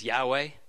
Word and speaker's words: Yahweh: So we have the letters, YHWH, and an educated guess, a way of pronouncing that is Yahweh.